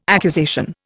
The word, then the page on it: accusation: Indian